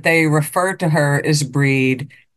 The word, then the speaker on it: Brigid: and it was beaten into me that you pronounced it Breed. You didn't pronounce it Bridget, it was pronounced Breed.